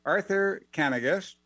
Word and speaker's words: Arthur Kanegis: Is it Kanegis? Can you correct me here? – Arthur Kanegis. – Kanegis. Okay, I got it wrong.